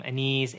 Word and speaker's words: Aniz: I'm also on Twitter at my name Anees A-N-I-Z.